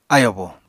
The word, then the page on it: ayobo: apatani